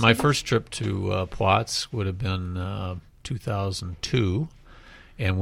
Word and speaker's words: Płock: And how about, I understand some of my neighbors spent some time in Płock. I learned how to say it this summer. It's not actually Plots. It's that L is a W sound.